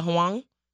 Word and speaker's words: Huang: Is it Huang or Huang? Huang, yeah. I was like, thank I don't you. Know how to pronounce it. I was going to spell it Huang. Yeah. – Yes. – Yeah. – Josie Huang.